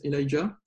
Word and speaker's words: Elijah: Donc en anglais, ça se prononce Elijah.